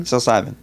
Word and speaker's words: psilocybin: And I literally cannot pronounce this word right. Psilocybin? – Psilocybin. – Yes.